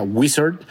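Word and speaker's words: Uizard: que en realidad se pronuncia Wizard.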